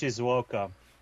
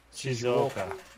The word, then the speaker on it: Shizuoka: Shizuoka. It's pronounced Shizuoka. – Shizuoka.